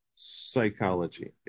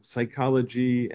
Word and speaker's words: psychology: Okay, we don't pronounce it like the Greeks do. The Greeks pronounce psuche. Okay, we don't do that. We just say psychology. Okay, we just ignore the P. Okay, psychology and other words.